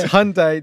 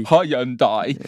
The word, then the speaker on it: Hyundai: It's Hyundai. This is how you pronounce it. That's the name globally. – Because of me going, Hyundai. – Yeah. – Putting too many syllables into it.